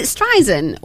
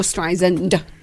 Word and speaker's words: Streisand: Is it Streisand or Streisand? – Barbara Streisand. – There's a D on the end of a name, Mum.